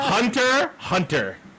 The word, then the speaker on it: Hunter x Hunter: No, it's Hunter x Hunter. Thank you. – No. – X is silent. – X is silent. X doesn't belong there. – X is silent. – X don't mean nothing. He just put it there for decoration.